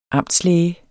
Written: amtslæge

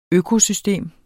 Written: økosystem

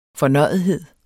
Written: fornøjethed

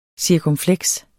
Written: cirkumfleks

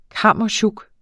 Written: kammesjuk

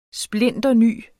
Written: splinterny

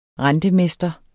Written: rentemester

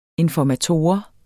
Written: informator

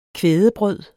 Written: kvædebrød

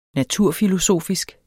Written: naturfilosofisk

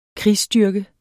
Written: krigsstyrke

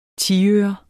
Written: tiøre

